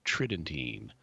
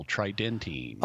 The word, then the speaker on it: Tridentine: Because I normally stress the first syllable, tridentine, but you were stressing the second syllable, tridentine.